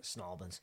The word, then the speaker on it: St. Albans: Because they pronounce it Snalbans.